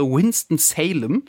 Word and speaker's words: Winston-Salem: Also seit 4 Tagen sind sie jetzt drüben, geht es dann am Montag auch endlich weiter ins WM-Basecamp nach, das habe ich gelernt, die Aussprache Winston-Salem.